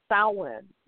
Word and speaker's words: Samhain: And it looks like it is pronounced Samhain because it is spelled Samhain. But one of the versions of how it is pronounced is Samhain, which is S-O-W and a hyphen I-N.